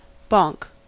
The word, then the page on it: bonk: American English